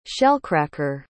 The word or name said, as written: shellcracker